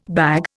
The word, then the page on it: bag: Indian